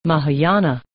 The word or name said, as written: mahayana